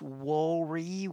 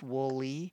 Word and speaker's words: Woolley: I referred to him as Bruce Woolley with an R, but it's just Woolley.